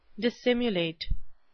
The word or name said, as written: dissimulate